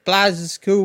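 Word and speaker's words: Blazkowicz: Team up with a friend or play solo as BJ Blaz's cool with his twin daughters. – The fact that you pronounce his name right makes me really happy.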